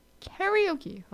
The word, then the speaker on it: karaoke: Another thing, karaoke. Karaoke. Karaoke. Japanese. Karaoke. Not karaoke. It is not pronounced that way. It is karaoke.